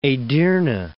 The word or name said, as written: Edirne